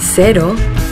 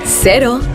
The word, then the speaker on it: cero: In Yucatan, the local Mexican surfers pronounce cero, cero. During the warm summer evenings in bars around the coast of Nayarit, you'll hear them say, cero. In New Zealand, zero alcohol Corona cero is pronounced cero.